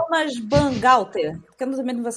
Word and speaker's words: Thomas Bangalter: Guy Manuel de Homem Cristo e Thomas Bangalter.